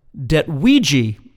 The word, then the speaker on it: Detwiije: We heard a band whose name I believe is pronounced Detwiije, or Detwiije, D-E-T-W-I-I-J-E, and their song is called Would You Rather Be Followed by 40 Ducks for the Rest of Your Life?